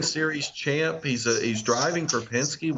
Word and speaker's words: Penske: And he's the third guy in line at Penske on that list right there, which is a little surprising to me. – Yeah, the third – hey, you said that right.